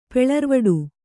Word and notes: ಪೆಳರ್ವಡು: Kannada

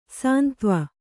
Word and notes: ಸೈಂದವ: saindava

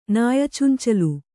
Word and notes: ನಾಯಚುಂಚಲು: Kannada

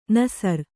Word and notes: ನಸರ್: nasar

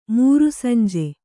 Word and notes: ಮೂರುಸಂಜೆ: mūru sanje

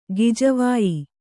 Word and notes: ಗಿಜವಾಯಿ: gijavāyi

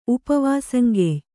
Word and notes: ಉಪವಾಸಂಗೆಯ್: Kannada

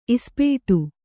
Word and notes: ಇಸ್ಪೇಟು: ispēṭu

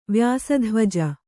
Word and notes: ವ್ಯಾಸಧ್ವಜ: vyāsa dhvaja

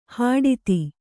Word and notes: ಹಟಾತ್ತು: Kannada

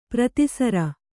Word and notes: ಪ್ರತಿಸರ: prati sara

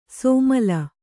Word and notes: ಸೋಮಲ: sōmala

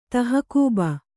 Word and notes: ತಹಕೂಬ: Kannada

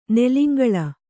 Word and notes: ನೆಲಿಂಗಳ: kannada

